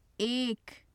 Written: ek

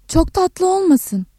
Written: tatlı

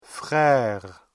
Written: frère

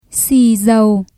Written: xì dầu

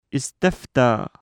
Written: اِسْتَفْتَى

